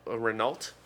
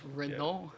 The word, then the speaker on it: Renault: My dad decided to do some electric work on his Renault, or as you Americans would call Renault. I pronounce it French, Renault. – Yeah, it's Renault.